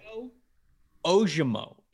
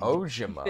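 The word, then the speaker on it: Ojomo: Moto Ojimo is how you say his name. – Ojimo.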